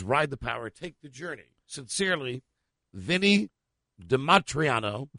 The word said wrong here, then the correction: DiMartino: DiMartino. – Sounded like Dimatriano. Sounded good. – Vinny DiMart. – How do you spell it? – D-I-M. – Uh-huh. – A-T. – Uh-huh. – I-N-O. – DiMartino.